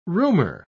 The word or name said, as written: rumor